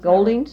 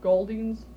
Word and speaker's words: Golding's: Like, there's Gold's, or there used to be, is that, am I pronouncing Golding's? – That right? Golding's.